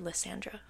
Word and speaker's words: Lysandra: She's, like, restraining herself from just ripping out Lysandra's throat. But then Evangeline goes on to explain that is it Lysandra or Lysandra? How do you say it? – I say Lysandra.